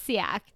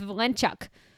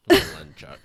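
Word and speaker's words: Walenciak: And I was like, well, it's Siak. Anyways, turns out the Polish pronounce it with a V, so it's Valenciak. – Valenciak.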